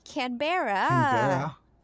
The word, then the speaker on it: Canberra: Did you say Canterbury? – Canterbury. How do you say it? – Canberra. Don't insult our neighbors to the south.